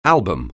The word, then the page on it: album: English